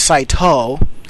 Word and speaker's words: Saito: The second character, To, pronounced with long O, so it's Saito, can also be read as Fuji and means wisteria.